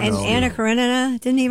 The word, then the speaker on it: Karenina: It's Karenina. You're right. – Thank you. – Karenina.